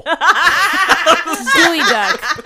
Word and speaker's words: geoduck: but gooey duck is also spelled Geoduck, right? – Yeah, kind of, I think. – But the Geoduck Pokemon is spelled Geoduck and pronounced Geoduck? – Yes.